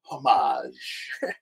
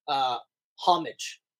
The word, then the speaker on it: homage: I remember the first time I saw that word, I actually pronounced it homage. – Homage. It looks like homage. – I was just like, homage. And then I heard someone say homage, and I was like, how would you have gotten that pronunciation? – Really? You go the French way with this?